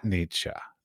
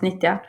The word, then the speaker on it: Nitja: So like if somebody were going to propose a solo show at Nitja, did I pronounce it correctly? – Almost Nitja. – Oh, so close.